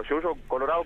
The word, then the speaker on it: Yuyo Colorado: Claro, Yuyo Colorado, como pronunciamos en el norte, sin D.